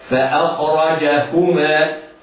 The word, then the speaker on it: فأخرجهما: قد يقول هكذا فأخرجهما مما كان فيه، لم نسمع هاء، وإنما على القارئ أن يعتني بنطق الهاء هكذا فأخرجهما.